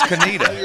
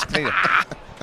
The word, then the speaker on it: Conetoe: See, it's Conetoe. – Yeah. – It's Conetoe. I don't know what's going on in that little section of eastern North Carolina, but the spelling and the pronunciation just is not exactly.